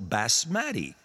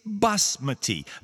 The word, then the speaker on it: basmati: Not knowing anything else, reading the word, someone would probably accent it on the second syllable, basmati. But the proper pronunciation, and it's not a hard pronunciation. There's no sounds that you have to learn. There's no difference. It's basmati. The accent is on the first syllable.